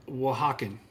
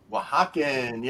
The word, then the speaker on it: Oaxacan: You're the reason that I understand how to pronounce the word Oaxacan. – Oaxacan, yeah.